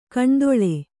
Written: ಕಣ್ದೊಳೆ

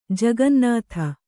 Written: ಜಗನ್ನುತ

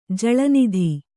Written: ಜಳನಿಧಿ

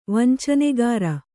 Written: ವಂಚನೆಗಾರ

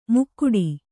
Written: ಮುಕ್ಕುಡಿ

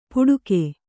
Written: ಪುಡುಕೆ

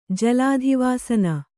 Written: ಜಲಾಧಿವಾಸನ

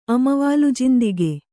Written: ಅಮವಾಲುಜಿಂದಿಗೆ

